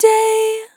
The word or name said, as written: Short Day